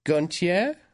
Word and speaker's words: Gontier: I like that you're putting the accent on it. – I should learn how to say it probably before tomorrow. Adam Gontier. It's not Gontier. – Gontier. – Gontier. – Mm-hmm. – Gontier. – There you go.